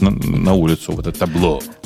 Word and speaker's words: Tableau: Ты, ты, Tableau. Это вы так такое произносите, да? Табло. Ну, ну да, так, так они их называют. – Табло они называются.